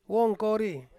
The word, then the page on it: wongkori: Ansus